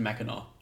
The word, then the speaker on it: Mackinac: But it's spelled like Mackinac, but it's pronounced Mackinac.